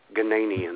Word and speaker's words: Ghanaian: And he was kind of a nitpicker, and he said, you need to tell Ma Tibbles that the correct pronunciation is Ghanaian.